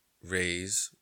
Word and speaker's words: Reyes: But it's originally pronounced Reyes with a fucking A. A-Y-E-S, not E-E-Y-E-S.